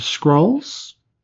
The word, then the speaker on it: Skrull: S-K-R-U-L-L. – That is... – Skrull. – Okay, I did not need you to spell it, smartass. I wanted you to say it. – Well, it helps. – Because it sounds like you're saying scroll.